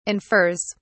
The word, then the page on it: infers: British English